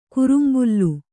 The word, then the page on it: ಕರಂಬಳ: karambaḷa